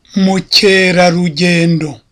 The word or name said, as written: mukerarugendo